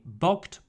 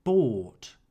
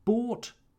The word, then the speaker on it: bought: By in the simple past is pronounced bought. The GH is silent. I bought bread.